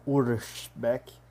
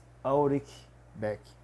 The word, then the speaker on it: Ulrich Beck: No alemão Ulrich Beck e no, no inglês Ulrich Beck.